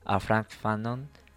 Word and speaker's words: Frantz Fanon: Para cerrar ese concepto de negrofobia, justo cito a Frantz Fanon. Espero que lo haya pronunciado bien. – Sí, ama Frantz Fanon.